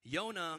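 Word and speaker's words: Yona (יונה): Yona is the Hebrew pronunciation of Jonah.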